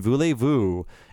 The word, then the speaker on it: Voulez-vous: The EZ is always pronounced AY in French, like in Shay and Voulez-vous. And don't pronounce the Z at the end of voo unless the next word starts with a vowel.